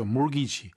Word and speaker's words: mortgage: 그리고 mortgage, 이게 원래 주택 장기 대출, 주택 융자 이럴 때 쓰이는 단어인데 T가 발음이 안 돼요. 알았죠? mortgage.